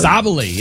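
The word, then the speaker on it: Sabally: Am I saying it right? Sabali. – Sabali, yeah.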